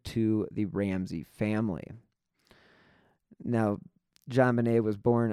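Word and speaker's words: JonBenét: And some other interesting facts is that JonBenet was named after her father, John Bennett Ramsey, and her name is pronounced in a French style, JonBenet.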